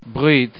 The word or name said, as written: brit